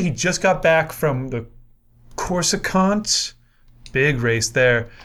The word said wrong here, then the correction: Coruscant: Is that like Coruscant? – Yes, that's how it's pronounced.